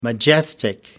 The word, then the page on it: majestic: American English